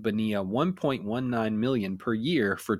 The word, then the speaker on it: Bonilla: Just say his name. It's Bonilla.